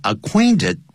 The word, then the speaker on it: acquainted: acquainted with, A-C-Q-U-A-I-N-T-E-D, acquainted. 中文呢我们老中呢通常会念成 acquainted, 不要这样念 acquainted, aiding, acquainted, 这样用法 with the local routes,